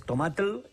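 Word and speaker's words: tomatl: Entonces esa palabra es tomatel y nosotros lo pronunciamos como tomate.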